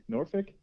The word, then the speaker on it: Norfolk: You don't pronounce the L, right? – It's more like Fick, Norfolk.